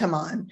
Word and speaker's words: Haman: And what is also interesting is that Haman, I call him Haman, Haman, whatever, it's pronounced Haman, sends the orders nearly a year in advance of the chosen date.